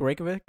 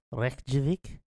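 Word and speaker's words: Reykjavík: I'm like driving down to, you know, is it Reykjavik? Reykjavik? Is I saying that right? – Sure, close enough. – Reykjavik?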